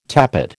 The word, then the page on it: tappet: American English